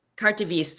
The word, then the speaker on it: carte de visite: Carte de visite. I hope I'm saying that right. V-I-S-I-T-E, if I'm saying that correctly. Yeah. – Right. Yeah. Carte de visite.